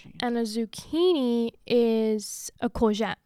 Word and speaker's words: courgette: Courgette, is that how you say it? – A courgette, yeah.